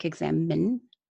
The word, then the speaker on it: examen: Okay, it's spelled E-X-A-M-E-N, like examine.